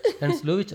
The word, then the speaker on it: Ernst Lubitsch: Este mismo Ernst Lubitsch, ¿cómo se dice? – Ernst Lubitsch, era alemán, ¿no?